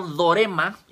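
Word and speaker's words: dorema (δώρημα): Y la palabra don viene del griego dorema, igual con acento en la O, que significa también regalo.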